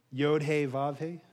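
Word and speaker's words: Yod-Heh-Vav-Heh (יהוה): And I'd try to say the Hebrew, but I'm probably going to say it wrong. My wife will correct me after church. Yod-Heh-Vav-Heh, is that right? Okay, I said it right.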